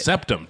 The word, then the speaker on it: septum: I see your skull here, and you have this is proving you have a deviated spec. Well, how do you say it? – Septum. Jesus. – Septum. I was thinking spectrum, but I was that? – Like, that's not it. A deviated septum.